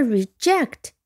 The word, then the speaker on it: reject: 那么 reject 它的读音也很简单，re 加上 ject。如果按照读音规则，你可以拼出来了吗？后面是 j e c t，j e c t 读成 ject，这也是一个后缀啊。未来有机会我们可以讲一下 reject，重音在第二音节，我们再拼一遍 r e j e c t，c t 在这里读成 cut，j e 读成 j。